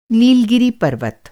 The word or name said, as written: नीलगिरि पर्वत